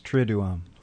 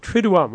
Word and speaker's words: Triduum: The Triduum. But again, say it one more time. – It's got two U's. Triduum. – Triduum.